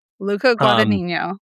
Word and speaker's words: Luca Guadagnino: I think, in fact, Luca Guadagnino, I will never pronounce this correctly. – Luca Guadagnino.